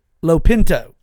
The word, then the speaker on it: Lopinto: I pronounce his name, I'm sorry. Lopinto. It's L-O-P-I-N-T-O. Lopinto.